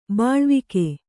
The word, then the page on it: ಬೆಳ್ವಕ್ಕಿ: beḷvakki